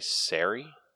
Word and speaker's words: Sari: S-A-R-I. Sorry? – Yeah. I'm gonna say Sari.